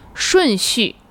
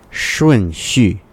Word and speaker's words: 顺序: And the last one is 顺序。 – 顺序。 Ooh, did I say that right? – Oh, this one is tricky. – It is a tough pronunciation. – 顺。 – 顺。 – Mm. – 顺序。 – 对, 很好。